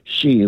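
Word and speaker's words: Xi: Hey, Tom, while I have you on the air, the Chinese word that is spelled X-I is pronounced she, like the English word she, as in he, she, it, not she.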